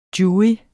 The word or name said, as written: jurys